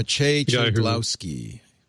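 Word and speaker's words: Maciej Cegłowski: I don't want to try and pronounce his actual name, but Maciej Czaglowski.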